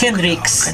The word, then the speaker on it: Hendrix: Le dije, por favor, pronuncie las últimas letras. Hendrix.